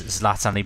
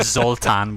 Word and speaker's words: Zlatan: So I think Abrahamovic was done a couple of times, and Zoltan was sort of offered up a few times.